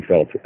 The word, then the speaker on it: Felts: at me on Twitter at Danny Felt, F-E-L-T-S is how my last name is spelled.